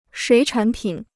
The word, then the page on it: 水产品: shuǐ chǎn pǐn